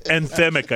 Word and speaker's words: anthemic: That did you say anthematic? – Yes. – Anthemic, I think, is the word.